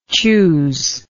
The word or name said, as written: chuse